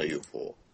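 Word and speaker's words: 遊穂: UFO ってどんな UFO？ – あ、そうか。 UFO じゃん。 – 遊ぶ方とか、稲穂の方と書いて。 UFO。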